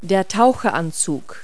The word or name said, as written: wetsuit